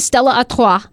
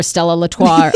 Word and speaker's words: Stella Artois: I never pronounce the shit right. I'm like, Stella Artois. Stella Artois, please.